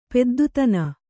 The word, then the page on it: ಪೆದ್ದುತನ: kannada